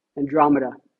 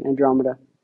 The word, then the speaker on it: Andromeda: Andromeda." I know I was saying it wrong. Andromeda.